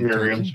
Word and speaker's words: Merriam's: Because I know the Merriam, is that how you pronounce it? Merriam? – Merriam's, yeah.